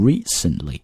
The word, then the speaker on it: recently: 我们在读的时候呢，中国同学经常把它读成 recently 啊，这个 t 拉的太多了。我们完全可以做一个单词内的失去爆破，所以读的时候呢，你可以读成 recently。